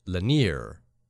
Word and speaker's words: Lanier: I'm from Georgia, and it's pronounced Lanier, but my pronunciation made for a good laugh. See, that's the I-E-R ending, and in Canada that is like yay. Like yay, right? Like Lake Lanier. That's just the colloquialism, I guess. But since this person is from Georgia, Lanier makes more sense.